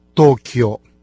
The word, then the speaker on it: Токио: Японцы же пользуются тем же названием, но произносят эти два иероглифа по-японски – Токио.